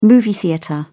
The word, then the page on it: movie theater: British English